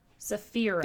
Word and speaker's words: Saphira: No, Saphira. – N. Saphira. R, not N. – Saphira.